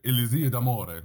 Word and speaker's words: Elisir d'Amore: But then also I was learning Dulcamara in Donizetti's Elixir d'Amore, Elisir d'Amore, let me say it correctly.